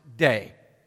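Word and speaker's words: day (δέ): There's a little word pronounced day, D-E, day.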